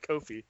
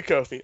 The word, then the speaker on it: Kofi: It's almost like coffee, but pronounced different. – We can go with Kofi. We can do Kofi.